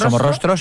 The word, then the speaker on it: Somorrostro: Playa Somorrostro. – Somorrostro, sí.